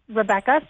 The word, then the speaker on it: Rebecca: It's Rebecca, so my name is spelled the traditional way. It's R-E-B-E-C-C-A.